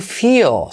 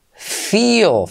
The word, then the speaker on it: feel: Número 8, number 8, de estos 47 verbos más usados es el verbo to feel, sentir. Feel. Fijaos cómo se pronuncia. Feel. Letra clave L, pero casi no se oye.